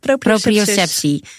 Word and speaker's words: proprioceptie: Op een congres hoor je daarover, over proprioceptie. Zeg ik het goed? – Ja, proprioceptie.